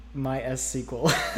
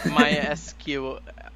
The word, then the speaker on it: MySQL: MySQL. – MySQL.